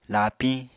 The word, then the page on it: laapiñ: Apatani